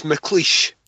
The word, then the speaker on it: McLeish: Yeah, it's McLeish. So no, think leash.